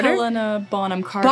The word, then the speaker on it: Helena Bonham Carter: Helena Bonham Carter.